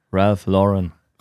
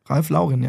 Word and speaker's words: Ralph Lauren: Ralph Lauren. Ralph Lauren, ja.